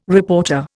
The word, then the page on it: reporter: Indian